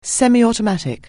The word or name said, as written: semi-automatic